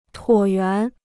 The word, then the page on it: 椭圆: tuǒ yuán